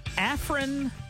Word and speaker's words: Afrin: Got some Afrin nasal spray. Is that how you say it? – Yeah, here's the thing with Afrin.